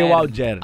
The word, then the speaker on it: Alger: E o Mouloudia Clube de Alger. Alger. – Alger. Alger. O, o D é, é, mudo. O D não, não existe.